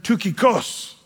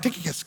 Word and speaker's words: Tychicus: Now, if you're a Greek person, you would have said Tychicus. That's how you'd say his name. But I like Tychicus because it sounds cooler.